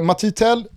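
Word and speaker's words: Mathys Tel: Men så att uh, eh, Matitel tror jag är uh, det korrekta franska uttalet på den här 17-åriga anfallaren.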